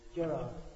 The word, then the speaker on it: Gerard: Gerard. – Come again? – Gerard. – How do you spell that? It's another language here to me. – G-E-R-A-R-D.